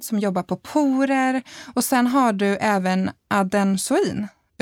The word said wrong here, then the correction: adenosin: Uttalar jag det där rätt nu? Ad adenosin? Ja. Tur att jag har med dig.